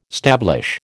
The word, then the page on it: stablish: American English